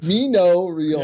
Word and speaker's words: Mino Raiola: You said Reno Miola. – What is his name? Mino Raiola? Yeah, I mixed it up. I got dyslexia. So Mino Raiola.